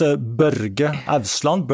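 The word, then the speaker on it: Børge Ousland: I'm going to mispronounce his name, Borg Osland. And how's his name pronounced? – I think you said it pretty good. – Pretty good, okay. – In Norwegian, it's Børge Ausland, but Borge Ausland.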